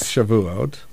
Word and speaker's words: Shavuot: Shavuot.